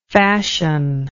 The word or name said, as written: fashionless